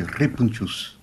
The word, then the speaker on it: répounchous: On dit répounchous. En roulant le R si on peut, c'est le nom occitan en fait.